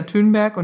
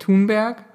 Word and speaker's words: Thunberg: Ähm, um ehrlich zu sein, meine, die erste große Revelation für mich bei der Recherche für diese Folge war, es heißt Greta Thunberg und nicht Thunberg.